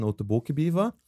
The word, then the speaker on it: Otoboke Beaver: Oto, also O-T-O-B-O-K-E. – -E. Und Beaver, so wie, wie Englisch. – Genau, Beaver sowie Bieber auf Englisch, genau. Otoboke Beaver. Otoboke, oder sie sagen Otoboke Beaver.